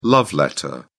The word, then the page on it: love letter: British English